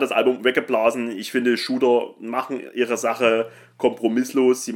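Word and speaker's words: Tsjuder: Und wir reden hier natürlich von Shooter. Geschrieben Zuder, ausgesprochen Shooter.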